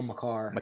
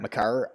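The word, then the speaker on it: Makar: Dude, they have – I'm looking at the roster now, and, like, I obviously we know, like, McKinnon – how do you say the rookie's name? Maker? Macker? I mean, obviously we all see his name. I don't know how to say it. – Kale McCarr. – McCarr.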